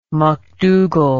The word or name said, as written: mcdougall